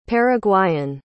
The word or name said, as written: paraguayan